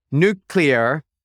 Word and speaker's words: nuclear: But what they said, is you might say it was nuclear, but instead kept pronouncing it nucle- nuclear, giving show a hard George W. Bush vibe buzzkill.